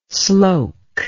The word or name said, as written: sloke